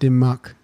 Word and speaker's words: Dumas: I think it's pronounced Dumas.